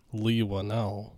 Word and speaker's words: Leigh Whannell: I think it's Leigh Whannell. – Leigh Whannell, is it? – That's how I would expect it to be pronounced.